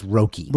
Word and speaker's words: Roki: This superstar, how do you say his name? Is it Roki Sasaki? – I believe it's Roki.